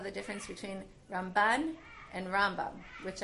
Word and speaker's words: Rambam: But Ramban, with the stress on the second syllable, actually moved to Israel. And in Israel, the way people speak Hebrew there, the accent is more likely to be put on the second syllable, as opposed to Rambam, who lived his life in Galut, where the stress is on the first syllable.